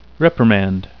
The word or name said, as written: reprimand